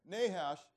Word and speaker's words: Nahash: It wasn't until Nahash, did I say that right? Is that his name, Nahash? Yeah, Nahash. It's not until Nahash says to him, okay, this is the condition.